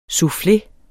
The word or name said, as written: SOUFFLEERNE